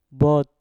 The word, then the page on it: bot: mpur